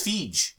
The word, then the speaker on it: Feige: Yeah, I've never known how to pronounce it. – I think it's Feige. – I always said Feige. – We're going with yours. – And this is Feige.